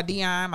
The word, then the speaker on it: Deonne: It's Art by Deonne. My name is spelled D, like Delta, E-O-N-N-E.